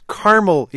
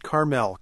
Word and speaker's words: Carmel: Well, I'm relieved to find that at least the internet doesn't yet do pronunciation very well, because it's Carmel, Indiana, not Carmel. Carmel is the name.